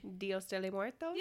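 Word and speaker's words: Día de los Muertos: I'm not going to say this right. Somebody say it for me. – Dios de los muertos? – Yes.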